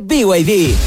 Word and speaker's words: BYD: ¿Los VID? – ¿VID? ¿No es VID? – Nada que ver. Se escribe BYD, pero se dice BYD.